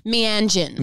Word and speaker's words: Mianjin: Tell me if I'm pronouncing it wrong, but Mianjin? – Oh, Mianjin. – Mianjin. – Mianjin. I mean, yeah, other people say it differently and I'm not going to correct. – I'll say the way you say it. – No, you say it like that. Keep saying it that way.